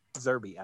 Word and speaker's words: Zerbe: Hey, it's Sean Connery, Richard Harris, and a young Anthony Zerbe. Zerbe, I pronounced it right at the time.